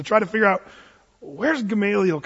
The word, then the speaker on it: Gamaliel: Gamaliel, if you want to be really savvy in your Greek pronunciation. Or you can say Gamaliel, if you're from Oklahoma.